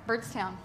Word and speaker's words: Byrdstown: Yeah, Byrdstown.